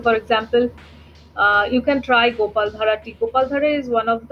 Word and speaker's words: Gopaldhara: Gopaldhara. – Thara. – Yes, D-H-A-R-A.